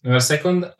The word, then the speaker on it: Never Second: Yes, Never Second, wow, eh, hai detto bene, volevo testarlo anche tu.